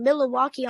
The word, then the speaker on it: Milwaukee: And they're playing Milwaukee on tomorrow. Both good teams, but Milwaukee is 52-20. – You do pronounce Milwaukee correctly.